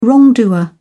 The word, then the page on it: wrongdoer: British English